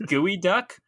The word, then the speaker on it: geoduck: It's pronounced Geoduck, but it's spelled Geoduck, and it's actually a type of clam.